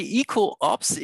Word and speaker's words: ecoObs: We say ecoobs, ecological observation. This is what it stands for.